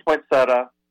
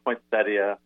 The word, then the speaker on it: poinsettia: Okay, I grew up saying poinsettia, but I say poinsettia now as a professional.